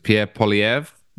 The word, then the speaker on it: Pierre Poilievre: But Trudeau has been a disaster in pretty much every aspect, and his presumed replacement, the leader of the Conservative Party, how do I say this, Pierre Poilievre, is that his name? It's really impressive. – Yes.